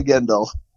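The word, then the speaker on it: Gindl: No, it's just Gindel. – It's Gindel? I think it's Jim because I see G-I-N and then D-L. – No, it's definitely Gindel.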